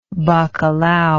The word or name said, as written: bacalao